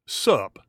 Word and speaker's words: SUP: Is that how you pronounce that? Hey, SUP. – Yes, that'd be correct.